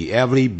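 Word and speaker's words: Everly: 因为这个合唱团呢是两兄弟 The Avery Brothers，有人呢念不好把它念成 The Avery 变成 E-V-E-R-Y， Avery 是每个，它不是这样的拼的，是 E-V-E-R-L-Y， The Avery Brothers，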